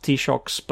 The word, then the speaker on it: Taoiseach: Yes, and it's spelled, it's like 14 characters long, and I cannot understand how it's supposed to be pronounced Taoiseach, but that's what Wikipedia says, so I'm going with that.